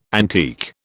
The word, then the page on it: antique: British English